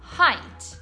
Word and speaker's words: height: Questa parola, che significa altezza, molto spesso viene pronunciata esattamente come il numero 8, e cioè 8. In realtà la pronuncia è un po' diversa. Infatti si pronuncia height. Height.